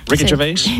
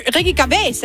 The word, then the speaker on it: Gervais: Den, som Ricky Gervais, komikeren, har lavet. Udtaler det rigtigt? Så står det over og griner. – Ricky Gervais. – Ricky Gervais, ja.